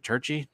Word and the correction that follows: Trichy: T-R-I-C-H-Y, Trichy.